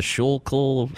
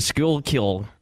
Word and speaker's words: Schuylkill: The Schuylkill. Look at the way that's spelled, and it's not possible.